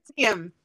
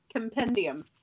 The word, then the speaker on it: Compendium: I can't pronounce that word, so can you do it? – Compendium. Compendium.